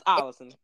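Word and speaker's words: Ollison: It's Olison. – It's spelled like illusion, but I don't think that's his name.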